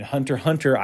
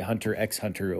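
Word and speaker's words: Hunter × Hunter: And Hunter Hunter, I call it Hunter Hunter because when I went to Japan, that's how they pronounce it over there. They don't pronounce the X.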